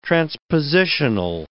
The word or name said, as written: transpositional